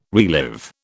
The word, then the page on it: relive: British English